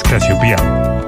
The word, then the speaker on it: Casiopea: Un minuto, se dice Casiopea, Casiopea, ¿cómo se dice? – En inglés, Casiopea. – Pero dijiste Casiopea. – Casiopea también se puede.